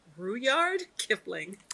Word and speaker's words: Rudyard Kipling: So my quote this week comes from, okay, Shanna, I hope I'm not going to mess up his name, but it's Rudyard Kipling.